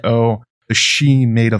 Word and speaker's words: sidhe: It's actually spelled S-I-D-H-E. People like myself who have seen that word in the past pronounce it Sidhe, but it's pronounced she basically.